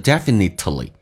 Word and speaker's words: definitely: 那同时这边有一个听力的一个关键点，当 definitely 这个单词我们通常喜欢说成 definitely，那这有个关键就是 t 跟 l 在一起的时候 t 是可以不发音的，就好像 exactly 可以说成 exactly， absolutely 说成 absolutely 一样的道理，所以记住Definitely, we definitely don't respect, 不去尊敬那些 the people who dish that dirt.